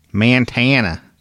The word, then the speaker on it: Montana: Montana. That's how they say it out there.